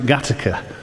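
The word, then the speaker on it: Gateacre: They spell it Gaytaker, but they pronounce it Gattaca.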